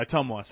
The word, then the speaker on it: Ottumwa: Yeah, Ottumwa, sorry. It was the pronunciation I was wondering.